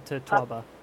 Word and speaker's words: totoaba: a fish called the totoaba. Am I pronouncing it correctly? – Yeah, the totoaba.